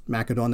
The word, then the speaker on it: Macedon: Ancient Macedon, and it is Macedon, they used a hard C.